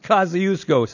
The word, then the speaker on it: Kosciuszko: It's pronounced Kosciuszko, starting with a K.